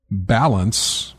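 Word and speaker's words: Ballance: Just a real quick note that I saw just recently, a game called Balance, but it's spelled Ball Ants, is being released on January 5th.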